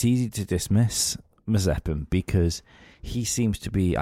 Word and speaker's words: Mazepin: I know his surname is Mazepin because we always pronounce it Marzipan, but he was driving the Mercedes,